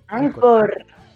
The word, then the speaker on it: Anchor: Anchor.